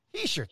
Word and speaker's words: Hischier: How do you say that? Hischier. Hischier. – thank Hischier. you very much.